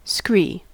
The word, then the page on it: scree: American English